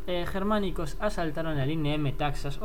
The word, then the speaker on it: Metaxas: Palos, piedras y pinchos puntiagudos, y le llamaron línea Metaxas. Sí, sí, como Metadona, pero en griego. Y el caso es que los germánicos desconcertados, pues atacaron. – ¿Sabes que probablemente se pronuncie Metajas? – Sí, pues Metajas, me rojas, me...